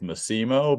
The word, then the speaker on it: Massimo: Is that correct pronunciation? – Yeah, I think it got butchered somewhere along the way, and my family says Massimo, but Massimo. – In the homeland, it's definitely Massimo in Italy.